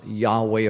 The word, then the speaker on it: Yahweh: No vowels were written, so it's believed to be pronounced Yahweh or Yahweh now.